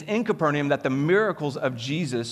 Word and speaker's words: Capernaum: You pronounce it Capernaum, right? You guys, about five of you said it. Then he went down to Capernaum.